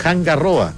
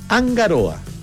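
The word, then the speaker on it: Hanga Roa: Lo mismo que algunos dicen Hangaroa, es Angaroa. La R no se pronuncia como una R, sino que como una R.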